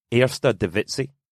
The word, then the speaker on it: Eerste Divisie: The top league is Eerste Divisie. – Eerste Divisie. – Divisie, yeah. As in Lizzie, he says.